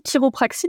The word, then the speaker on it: chiropraxie: Alors la chiropraxie, donc on dit chiropraxie, mais en effet ça s'écrit C-H-I-R-I.